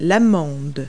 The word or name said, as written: almond